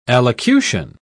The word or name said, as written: allocution